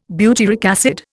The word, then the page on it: butyric acid: Indian